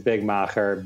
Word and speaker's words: Big Macher: My personal is Big Macher, B I G M A C H E R.